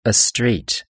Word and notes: a street: British English

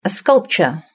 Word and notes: a sculpture: British English

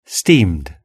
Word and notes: steamed: British English

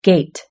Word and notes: gate: British English

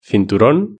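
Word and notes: cinturon: Spanish (Spain)

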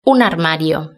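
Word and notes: un armario: Spanish (Spain)

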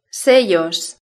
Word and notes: sellos: Spain Spanish

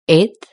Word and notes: eighth: British English

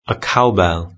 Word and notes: a cowbell: British English

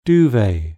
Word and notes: duvet: British English

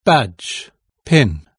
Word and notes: badge pin: British English

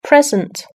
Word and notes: present: British English

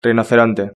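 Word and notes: rinoceronte: European Spanish

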